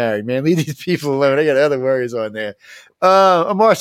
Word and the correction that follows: Amir: say your name for me, because I'm probably saying it wrong. – You're good. It's Amir. Amir Agoora. – Amir, okay, see? – Yeah.